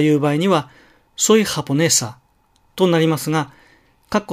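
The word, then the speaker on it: japonesa: 例えば、ハポネサという単語がありますね。これはねという音節にアクセントがあるわけですが、いつでもねが高くハポネサとだけ発音されるとは実は限らないんです。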